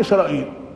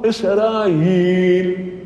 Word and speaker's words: إسرائيل: أشهر القراءات هي إسرائيل، قرئ وهي لغة إسرائيل بإبدال الهمزة ياء. بعضهم يقول إسرائيل هكذا نطقها وهو ما يناسب لسانهم، إسرائيل، فيها لغة أخرى وقراءة أخرى إسرائيل بكسر الهمزة غير الممدودة إسرائيل. أيضا قرئ بالألف مع الإمالة إسرائيل، قرئ بالألف من غير إمالة إسرائيل.